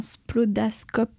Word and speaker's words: splodascope: Je crois qu'il va y avoir construction d'un splodascope. – C'est ça. Alors, tu l'as prononcé du premier coup, nickel.